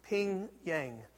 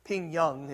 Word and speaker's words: Ping Yang: But his name is Ping, Ping Yang. Ping Young, as we would sometimes pronounce it in English.